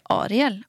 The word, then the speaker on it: Ariel: Jag bara, hur uttalar man ditt namn? För jag säger Ariel. Och jag tycker det är så fint. Han bara, du säger helt rätt.